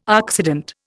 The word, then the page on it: oxidant: Indian